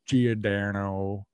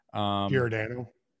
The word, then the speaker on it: Giordano: Giordano.